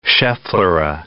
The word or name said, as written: schefflera